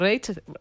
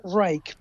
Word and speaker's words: Reich: Y él, a ver, se pronuncia, todo el mundo lo pronunciaba Reich, pero es Reich.